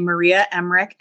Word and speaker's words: Emmerich: We are interviewing Maria Emmerich.